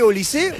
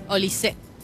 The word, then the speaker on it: Olise: ¿Cómo se dice? ¿Olise, Olise, Olis? – Olise. – Olise. – Olise, Olise, Olise. – Bien. Olise.